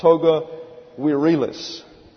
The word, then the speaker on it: virilis: V-I-R-I-L-I-S. Pronounced toga virilis.